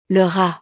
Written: le rat

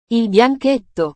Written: il bianchetto

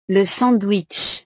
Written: le sandwich

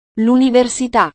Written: luniversita

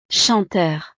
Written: chanteur

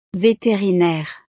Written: veterinaire

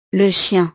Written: le chien

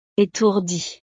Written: etourdi

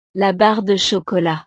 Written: la barre de chocolat

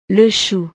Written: le chou

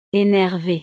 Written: enervee

